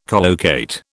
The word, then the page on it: collocate: British English